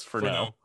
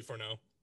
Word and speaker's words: Furneaux: It's that like Cajun type spelling. It's Furneaux. – That's usually pronounced O. – So it's Fourneaux. – So yeah, it would be Fourneaux.